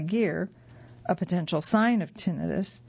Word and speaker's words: tinnitus: By the way, you may have also heard this referenced as tinnitus. Both pronunciations are correct. However, most medical professionals will use tinnitus.